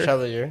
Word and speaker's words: Chevalier: Chevalier, yep.